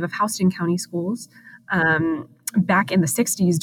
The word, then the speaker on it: Houston: You pronounced Houston County the right way.